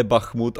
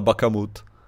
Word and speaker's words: Bachmut: který, která říkala ne Bachmut, ale Bakamut.